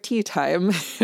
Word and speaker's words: Teatime: So they're hiring an assassin instead, and the Assassin's Guild assigns this to Mr. Tea Time. His name is actually pronounced Teatime, Mr. Teatime, yeah.